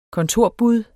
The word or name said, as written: kontorbud